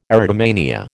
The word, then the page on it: erotomania: American English